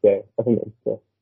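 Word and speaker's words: Zhou: We saw the Alfa Romeo squad, they picked up Joe, however, I think Crofty was pronouncing it Joe, but I think it is Joe.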